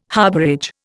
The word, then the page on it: harbourage: Indian